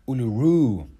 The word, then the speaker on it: Uluru: Uluru, sagt Shingi mit Betonung auf der letzten Silbe.